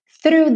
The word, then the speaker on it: through: Então, olha, through, lembrando uma palavra que aparece com alguma frequência, o GH. Pode fingir que não está lá, porque não vai ter pronúncia. E só lembrando de começar com o som do ffff, linguinha lá nos dentes da frente, porque a gente tem TH. E volta com essa língua já fazendo R.